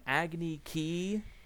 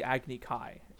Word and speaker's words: Agni Kai: And the child references an Agni Ki, which is this firebending duel. And even that in the show is called the Agni Kai. And it's spelled K-A-I. That is pronounced Kai every time.